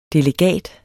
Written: delegat

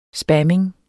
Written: spamming

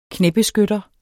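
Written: KNÆBESKYTTER

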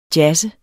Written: jazzet

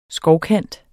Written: skovkant

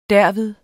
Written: derved

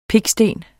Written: piksten

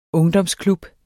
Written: ungdomsklub

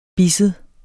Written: bisset

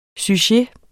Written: sujet

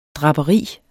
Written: draperi

